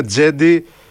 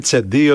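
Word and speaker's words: Cedi: Ναι, γι' αυτό το όνομά του προφέρεται, ας πούμε, Τζέντι και όχι Τσεντί, οτιδήποτε το λέγανε στην αρχή, Τζέντι Όσμαν.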